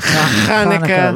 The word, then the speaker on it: Chanukah: Well, if you want to pronounce it with the CH, you'd go like a chet sound. Like Chanukah.